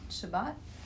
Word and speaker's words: Shabbat: This parallels the disagreement between the sages of the Talmud and the Tractate Shabbat, did you pronounce that right? – Yeah.